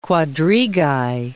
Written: quadrigae

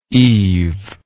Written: eaved

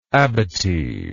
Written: abatised

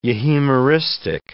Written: euhemeristic

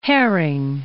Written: Hering

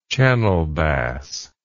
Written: channel bass

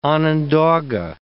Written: Onondaga

